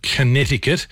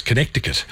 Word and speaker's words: Connecticut: US state of Connecticut. actually has got a C in it. So if you're doing it phonetically, it's Connecticut.